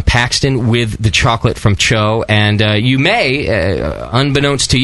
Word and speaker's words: Tcho: It's spelt weird. But it's pronounced Cho, T-C-H-O.